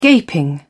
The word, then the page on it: gaping: British English